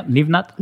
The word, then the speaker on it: Livnat: Yeah, Livnat.